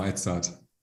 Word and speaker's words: Edzard: Genau, Edzard. – Und Spricht man, wie man schreibt.